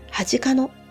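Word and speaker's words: 初鹿野: 有馬晴信が処刑されたのは、甲州市大和町恥かの、初めてという字に、鹿という字に野原の野で恥かのと読みますが、恥かのにある氷川という川のほとりで、有馬晴信、撤去の跡という解説版が立てられています。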